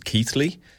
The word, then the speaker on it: Keighley: Genau, ich wohnte in der Nähe von einem kleinen Stadt namens Keighley. Wie es geschrieben wird, würde man nicht denken, dass es so ausgesprochen wird. Es ist K-E-I-G-H-L-E-Y.